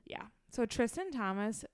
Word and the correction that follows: Thompson: Thompson. – Thompson. I always say his name. – Thomas? Thompson? – Thompson. Thompson. – Tristan Thompson. – There's a P.